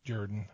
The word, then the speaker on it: Jordan: It's spelled Jordan, but it's near Auburn, so it's Jordan. That's how they say that.